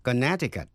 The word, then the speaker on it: Connecticut: Sau đây, âm si câm. Connecticut. Connecticut là tên tiểu bang, chữ âm si câm. Quý vị nghe lại. To connect là nối lại, liên hệ. To connect, âm si có phát âm. Connect, nhưng Connecticut, quý vị nghe lại. Connect, Connecticut. Và một từ ngữ nữa, Tucson. T-U-C-S-O-N, Tucson. T-U-C-S-O-N, âm C câm, đó là một thành phố ở Arizona.